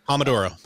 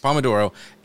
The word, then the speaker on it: Pomodoro: It was called Focus To-Do, and it actually has my task list built in, I think it's, is it Pomodoro? Is that how you say it? I don't ever know. – Yes, Pomodoro. – Pomodoro,